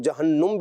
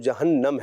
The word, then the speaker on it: جہنم: اس کو لوگ جہنم بھی پڑھتے ہیں، لیکن اس کا درست تلفظ جہنم ہے۔